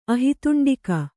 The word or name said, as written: ಆಹಿತುಂಡಿಕ